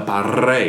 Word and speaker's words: parrilla: I get like some of my non-Wog friends, they go, who, the Parrilla? No, it's the Parrilla. – Yeah. That's right. It's you know, hard people, to roll your R. – Yeah, because people usually say Parrilla with the tongue, or they say Parrilla, just like one simple line.